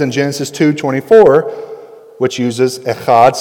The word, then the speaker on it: echad: So the Hebrew word for one in Deuteronomy 6:4 is echad, which is E-C-H-phlegm-A-D, okay? That's how you spell that, all right?